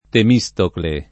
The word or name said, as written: Temistocle